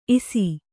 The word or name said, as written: ಇಸಿ